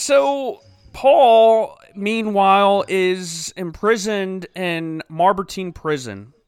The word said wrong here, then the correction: Mamertine: Is that how you pronounce that? Mamertine? Mamertine.